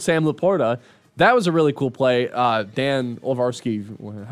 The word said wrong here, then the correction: Orlovsky: how do you say that name? Went over that. I saw what was it? – Nothing. He went up, whatever. – Didn't Dan Orlovsky?